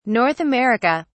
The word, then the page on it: North America: British English